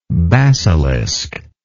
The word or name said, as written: basiliscan